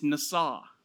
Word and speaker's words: nasa: It's nasa. If you're going to spell it in English, it says N-A-S-A. Nasa.